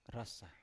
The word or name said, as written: ras sa